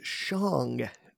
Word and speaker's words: Shang-Chi: That's why I'm really hoping Shang-Chi, which I think I've been mispronouncing all my life. – Yeah, that's recently come to light. I think everyone has. – Well, how's it supposed to be properly pronounced? – The official word on the street is that it's Shang-Chi.